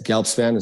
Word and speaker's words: Gelbspan: It's Gelbspan. It's my last name, Gelbspan Method.